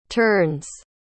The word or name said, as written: terns